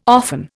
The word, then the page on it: often: Indian